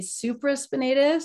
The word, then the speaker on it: supraspinatus: So you can correct my pronunciation of these things, but I had torn my supraspinatus.